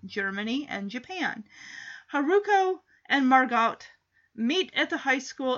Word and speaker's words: Margot: Margot. But it's just, I've always pronounced it as the T is silent. Because I think of Anne Frank, her sister's name is Margot. And it's spelled M-A-R-G-O-T.